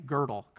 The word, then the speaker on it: Gödel: Uh, I actually didn't know how to pronounce that, but uh, math professor Jim Davis was in the first service, and afterwards he came up to me and was like, You pronounce it this way Gödel, Kurt Gödel.